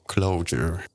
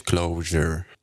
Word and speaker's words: Clojure: Na YouTubie znalazłem bardzo ciekawy wykład naszego rodaka Jana Stępnia, który opowiada o oczywiście współczesnym dialekcie języka Lisp, znanym jako Clojure, lub też można wymawiać Clojure.